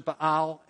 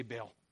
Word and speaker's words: Baal: And some would pronounce it Baal, and that's appropriate, but we generally just say Baal.